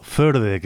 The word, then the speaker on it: Førde: Ellos estarán presentes en el Forde Festivalen, el festival de música tradicional y músicas del mundo de la localidad noruega de Førde, o Førde, que es como habría que pronunciarlo.